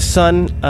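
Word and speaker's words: Sunn O))): Some people know them as Sun O, but actually, they're just pronounced Sun, even though their name is spelled S-U-N-N, and then there's an O, or a zero, and then three end brackets.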